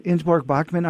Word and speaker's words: Ingeborg Bachmann: Clearly, you have a poetic and probably even an emotional relationship with a German poet, and I think I might be pronouncing her wrong, Ingeborg Bachmann? – Yeah, yeah, yes. – Ingeborg Bachmann.